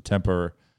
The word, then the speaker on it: Tampere: Yeah, so there's two teams in Tampere or Tempere, but they pronounce it Tempura.